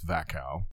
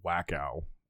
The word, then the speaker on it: Wachau: So in Austria, the, the region that this comes from is Wachau. A lot of you may see it and say Wachau, but you know, in the Austrian and Germanic languages, the W is pronounced as a V. So that's why it's Vacal.